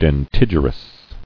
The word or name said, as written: Dentigerous